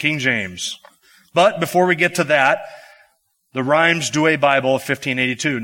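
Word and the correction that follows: Rheims: Now, just in case I pronounced that wrong, Paul, how would you say that? Rhymes Douay? Reams. Did I get Douay right? Okay, Reams Douay.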